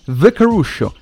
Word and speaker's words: The Caruscio: C'è chi dice Caruscio, c'è chi dice Caruscio. Ve lo dico io come si dice una volta per tutte, almeno non si sbaglia più nessuno. Signori, The Caruscio, con la pronuncia inglese che non fa mai male.